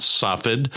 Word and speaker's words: Safed: Now, some would say it would have been Sfat, Safed, Safed. It's pronounced all different ways. In Hebrew, the word is Sfat, which is a city up on the western hills behind Capernaum. But the fact is, when you're in Capernaum, you would not be looking to the west.